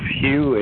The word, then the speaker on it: Hugh: It's Hugh, H-U-G-H. The last name is Charlson. It's spelled T like Tom, R-A-U-L-S-E-N like Nancy.